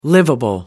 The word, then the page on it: liveable: American English